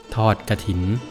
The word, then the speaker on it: ทอดกฐิน: ทอดกระถิน